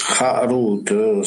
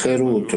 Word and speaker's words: Herut: Harut, scavato, non pronunciarlo Harut, ma Herut, libertà.